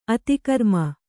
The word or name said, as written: ಅತಿಕರ್ಮ